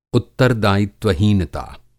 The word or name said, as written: उत्तरदायित्वहीनता